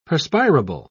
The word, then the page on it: perspirable: British English